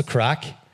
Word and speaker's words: craic: Yeah, what's the crack? – What's the crack? – And it, but it's spelled C-R-A-I-C.